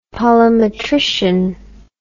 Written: polimetrician

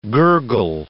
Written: gurgle

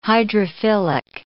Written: hydrophilic

